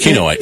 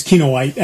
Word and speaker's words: Kinoite: You sure it's not Kinoite? Because it's a mineral. – It might be Kinoite, yeah. – Discovered by a Jesuit priest named Kino. So it's Kinoite.